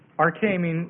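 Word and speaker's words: arche (ἀρχή): So A-R-C-H long E, arche meaning beginning or ancient, and then logos, L-O-G-O-S, meaning word or account.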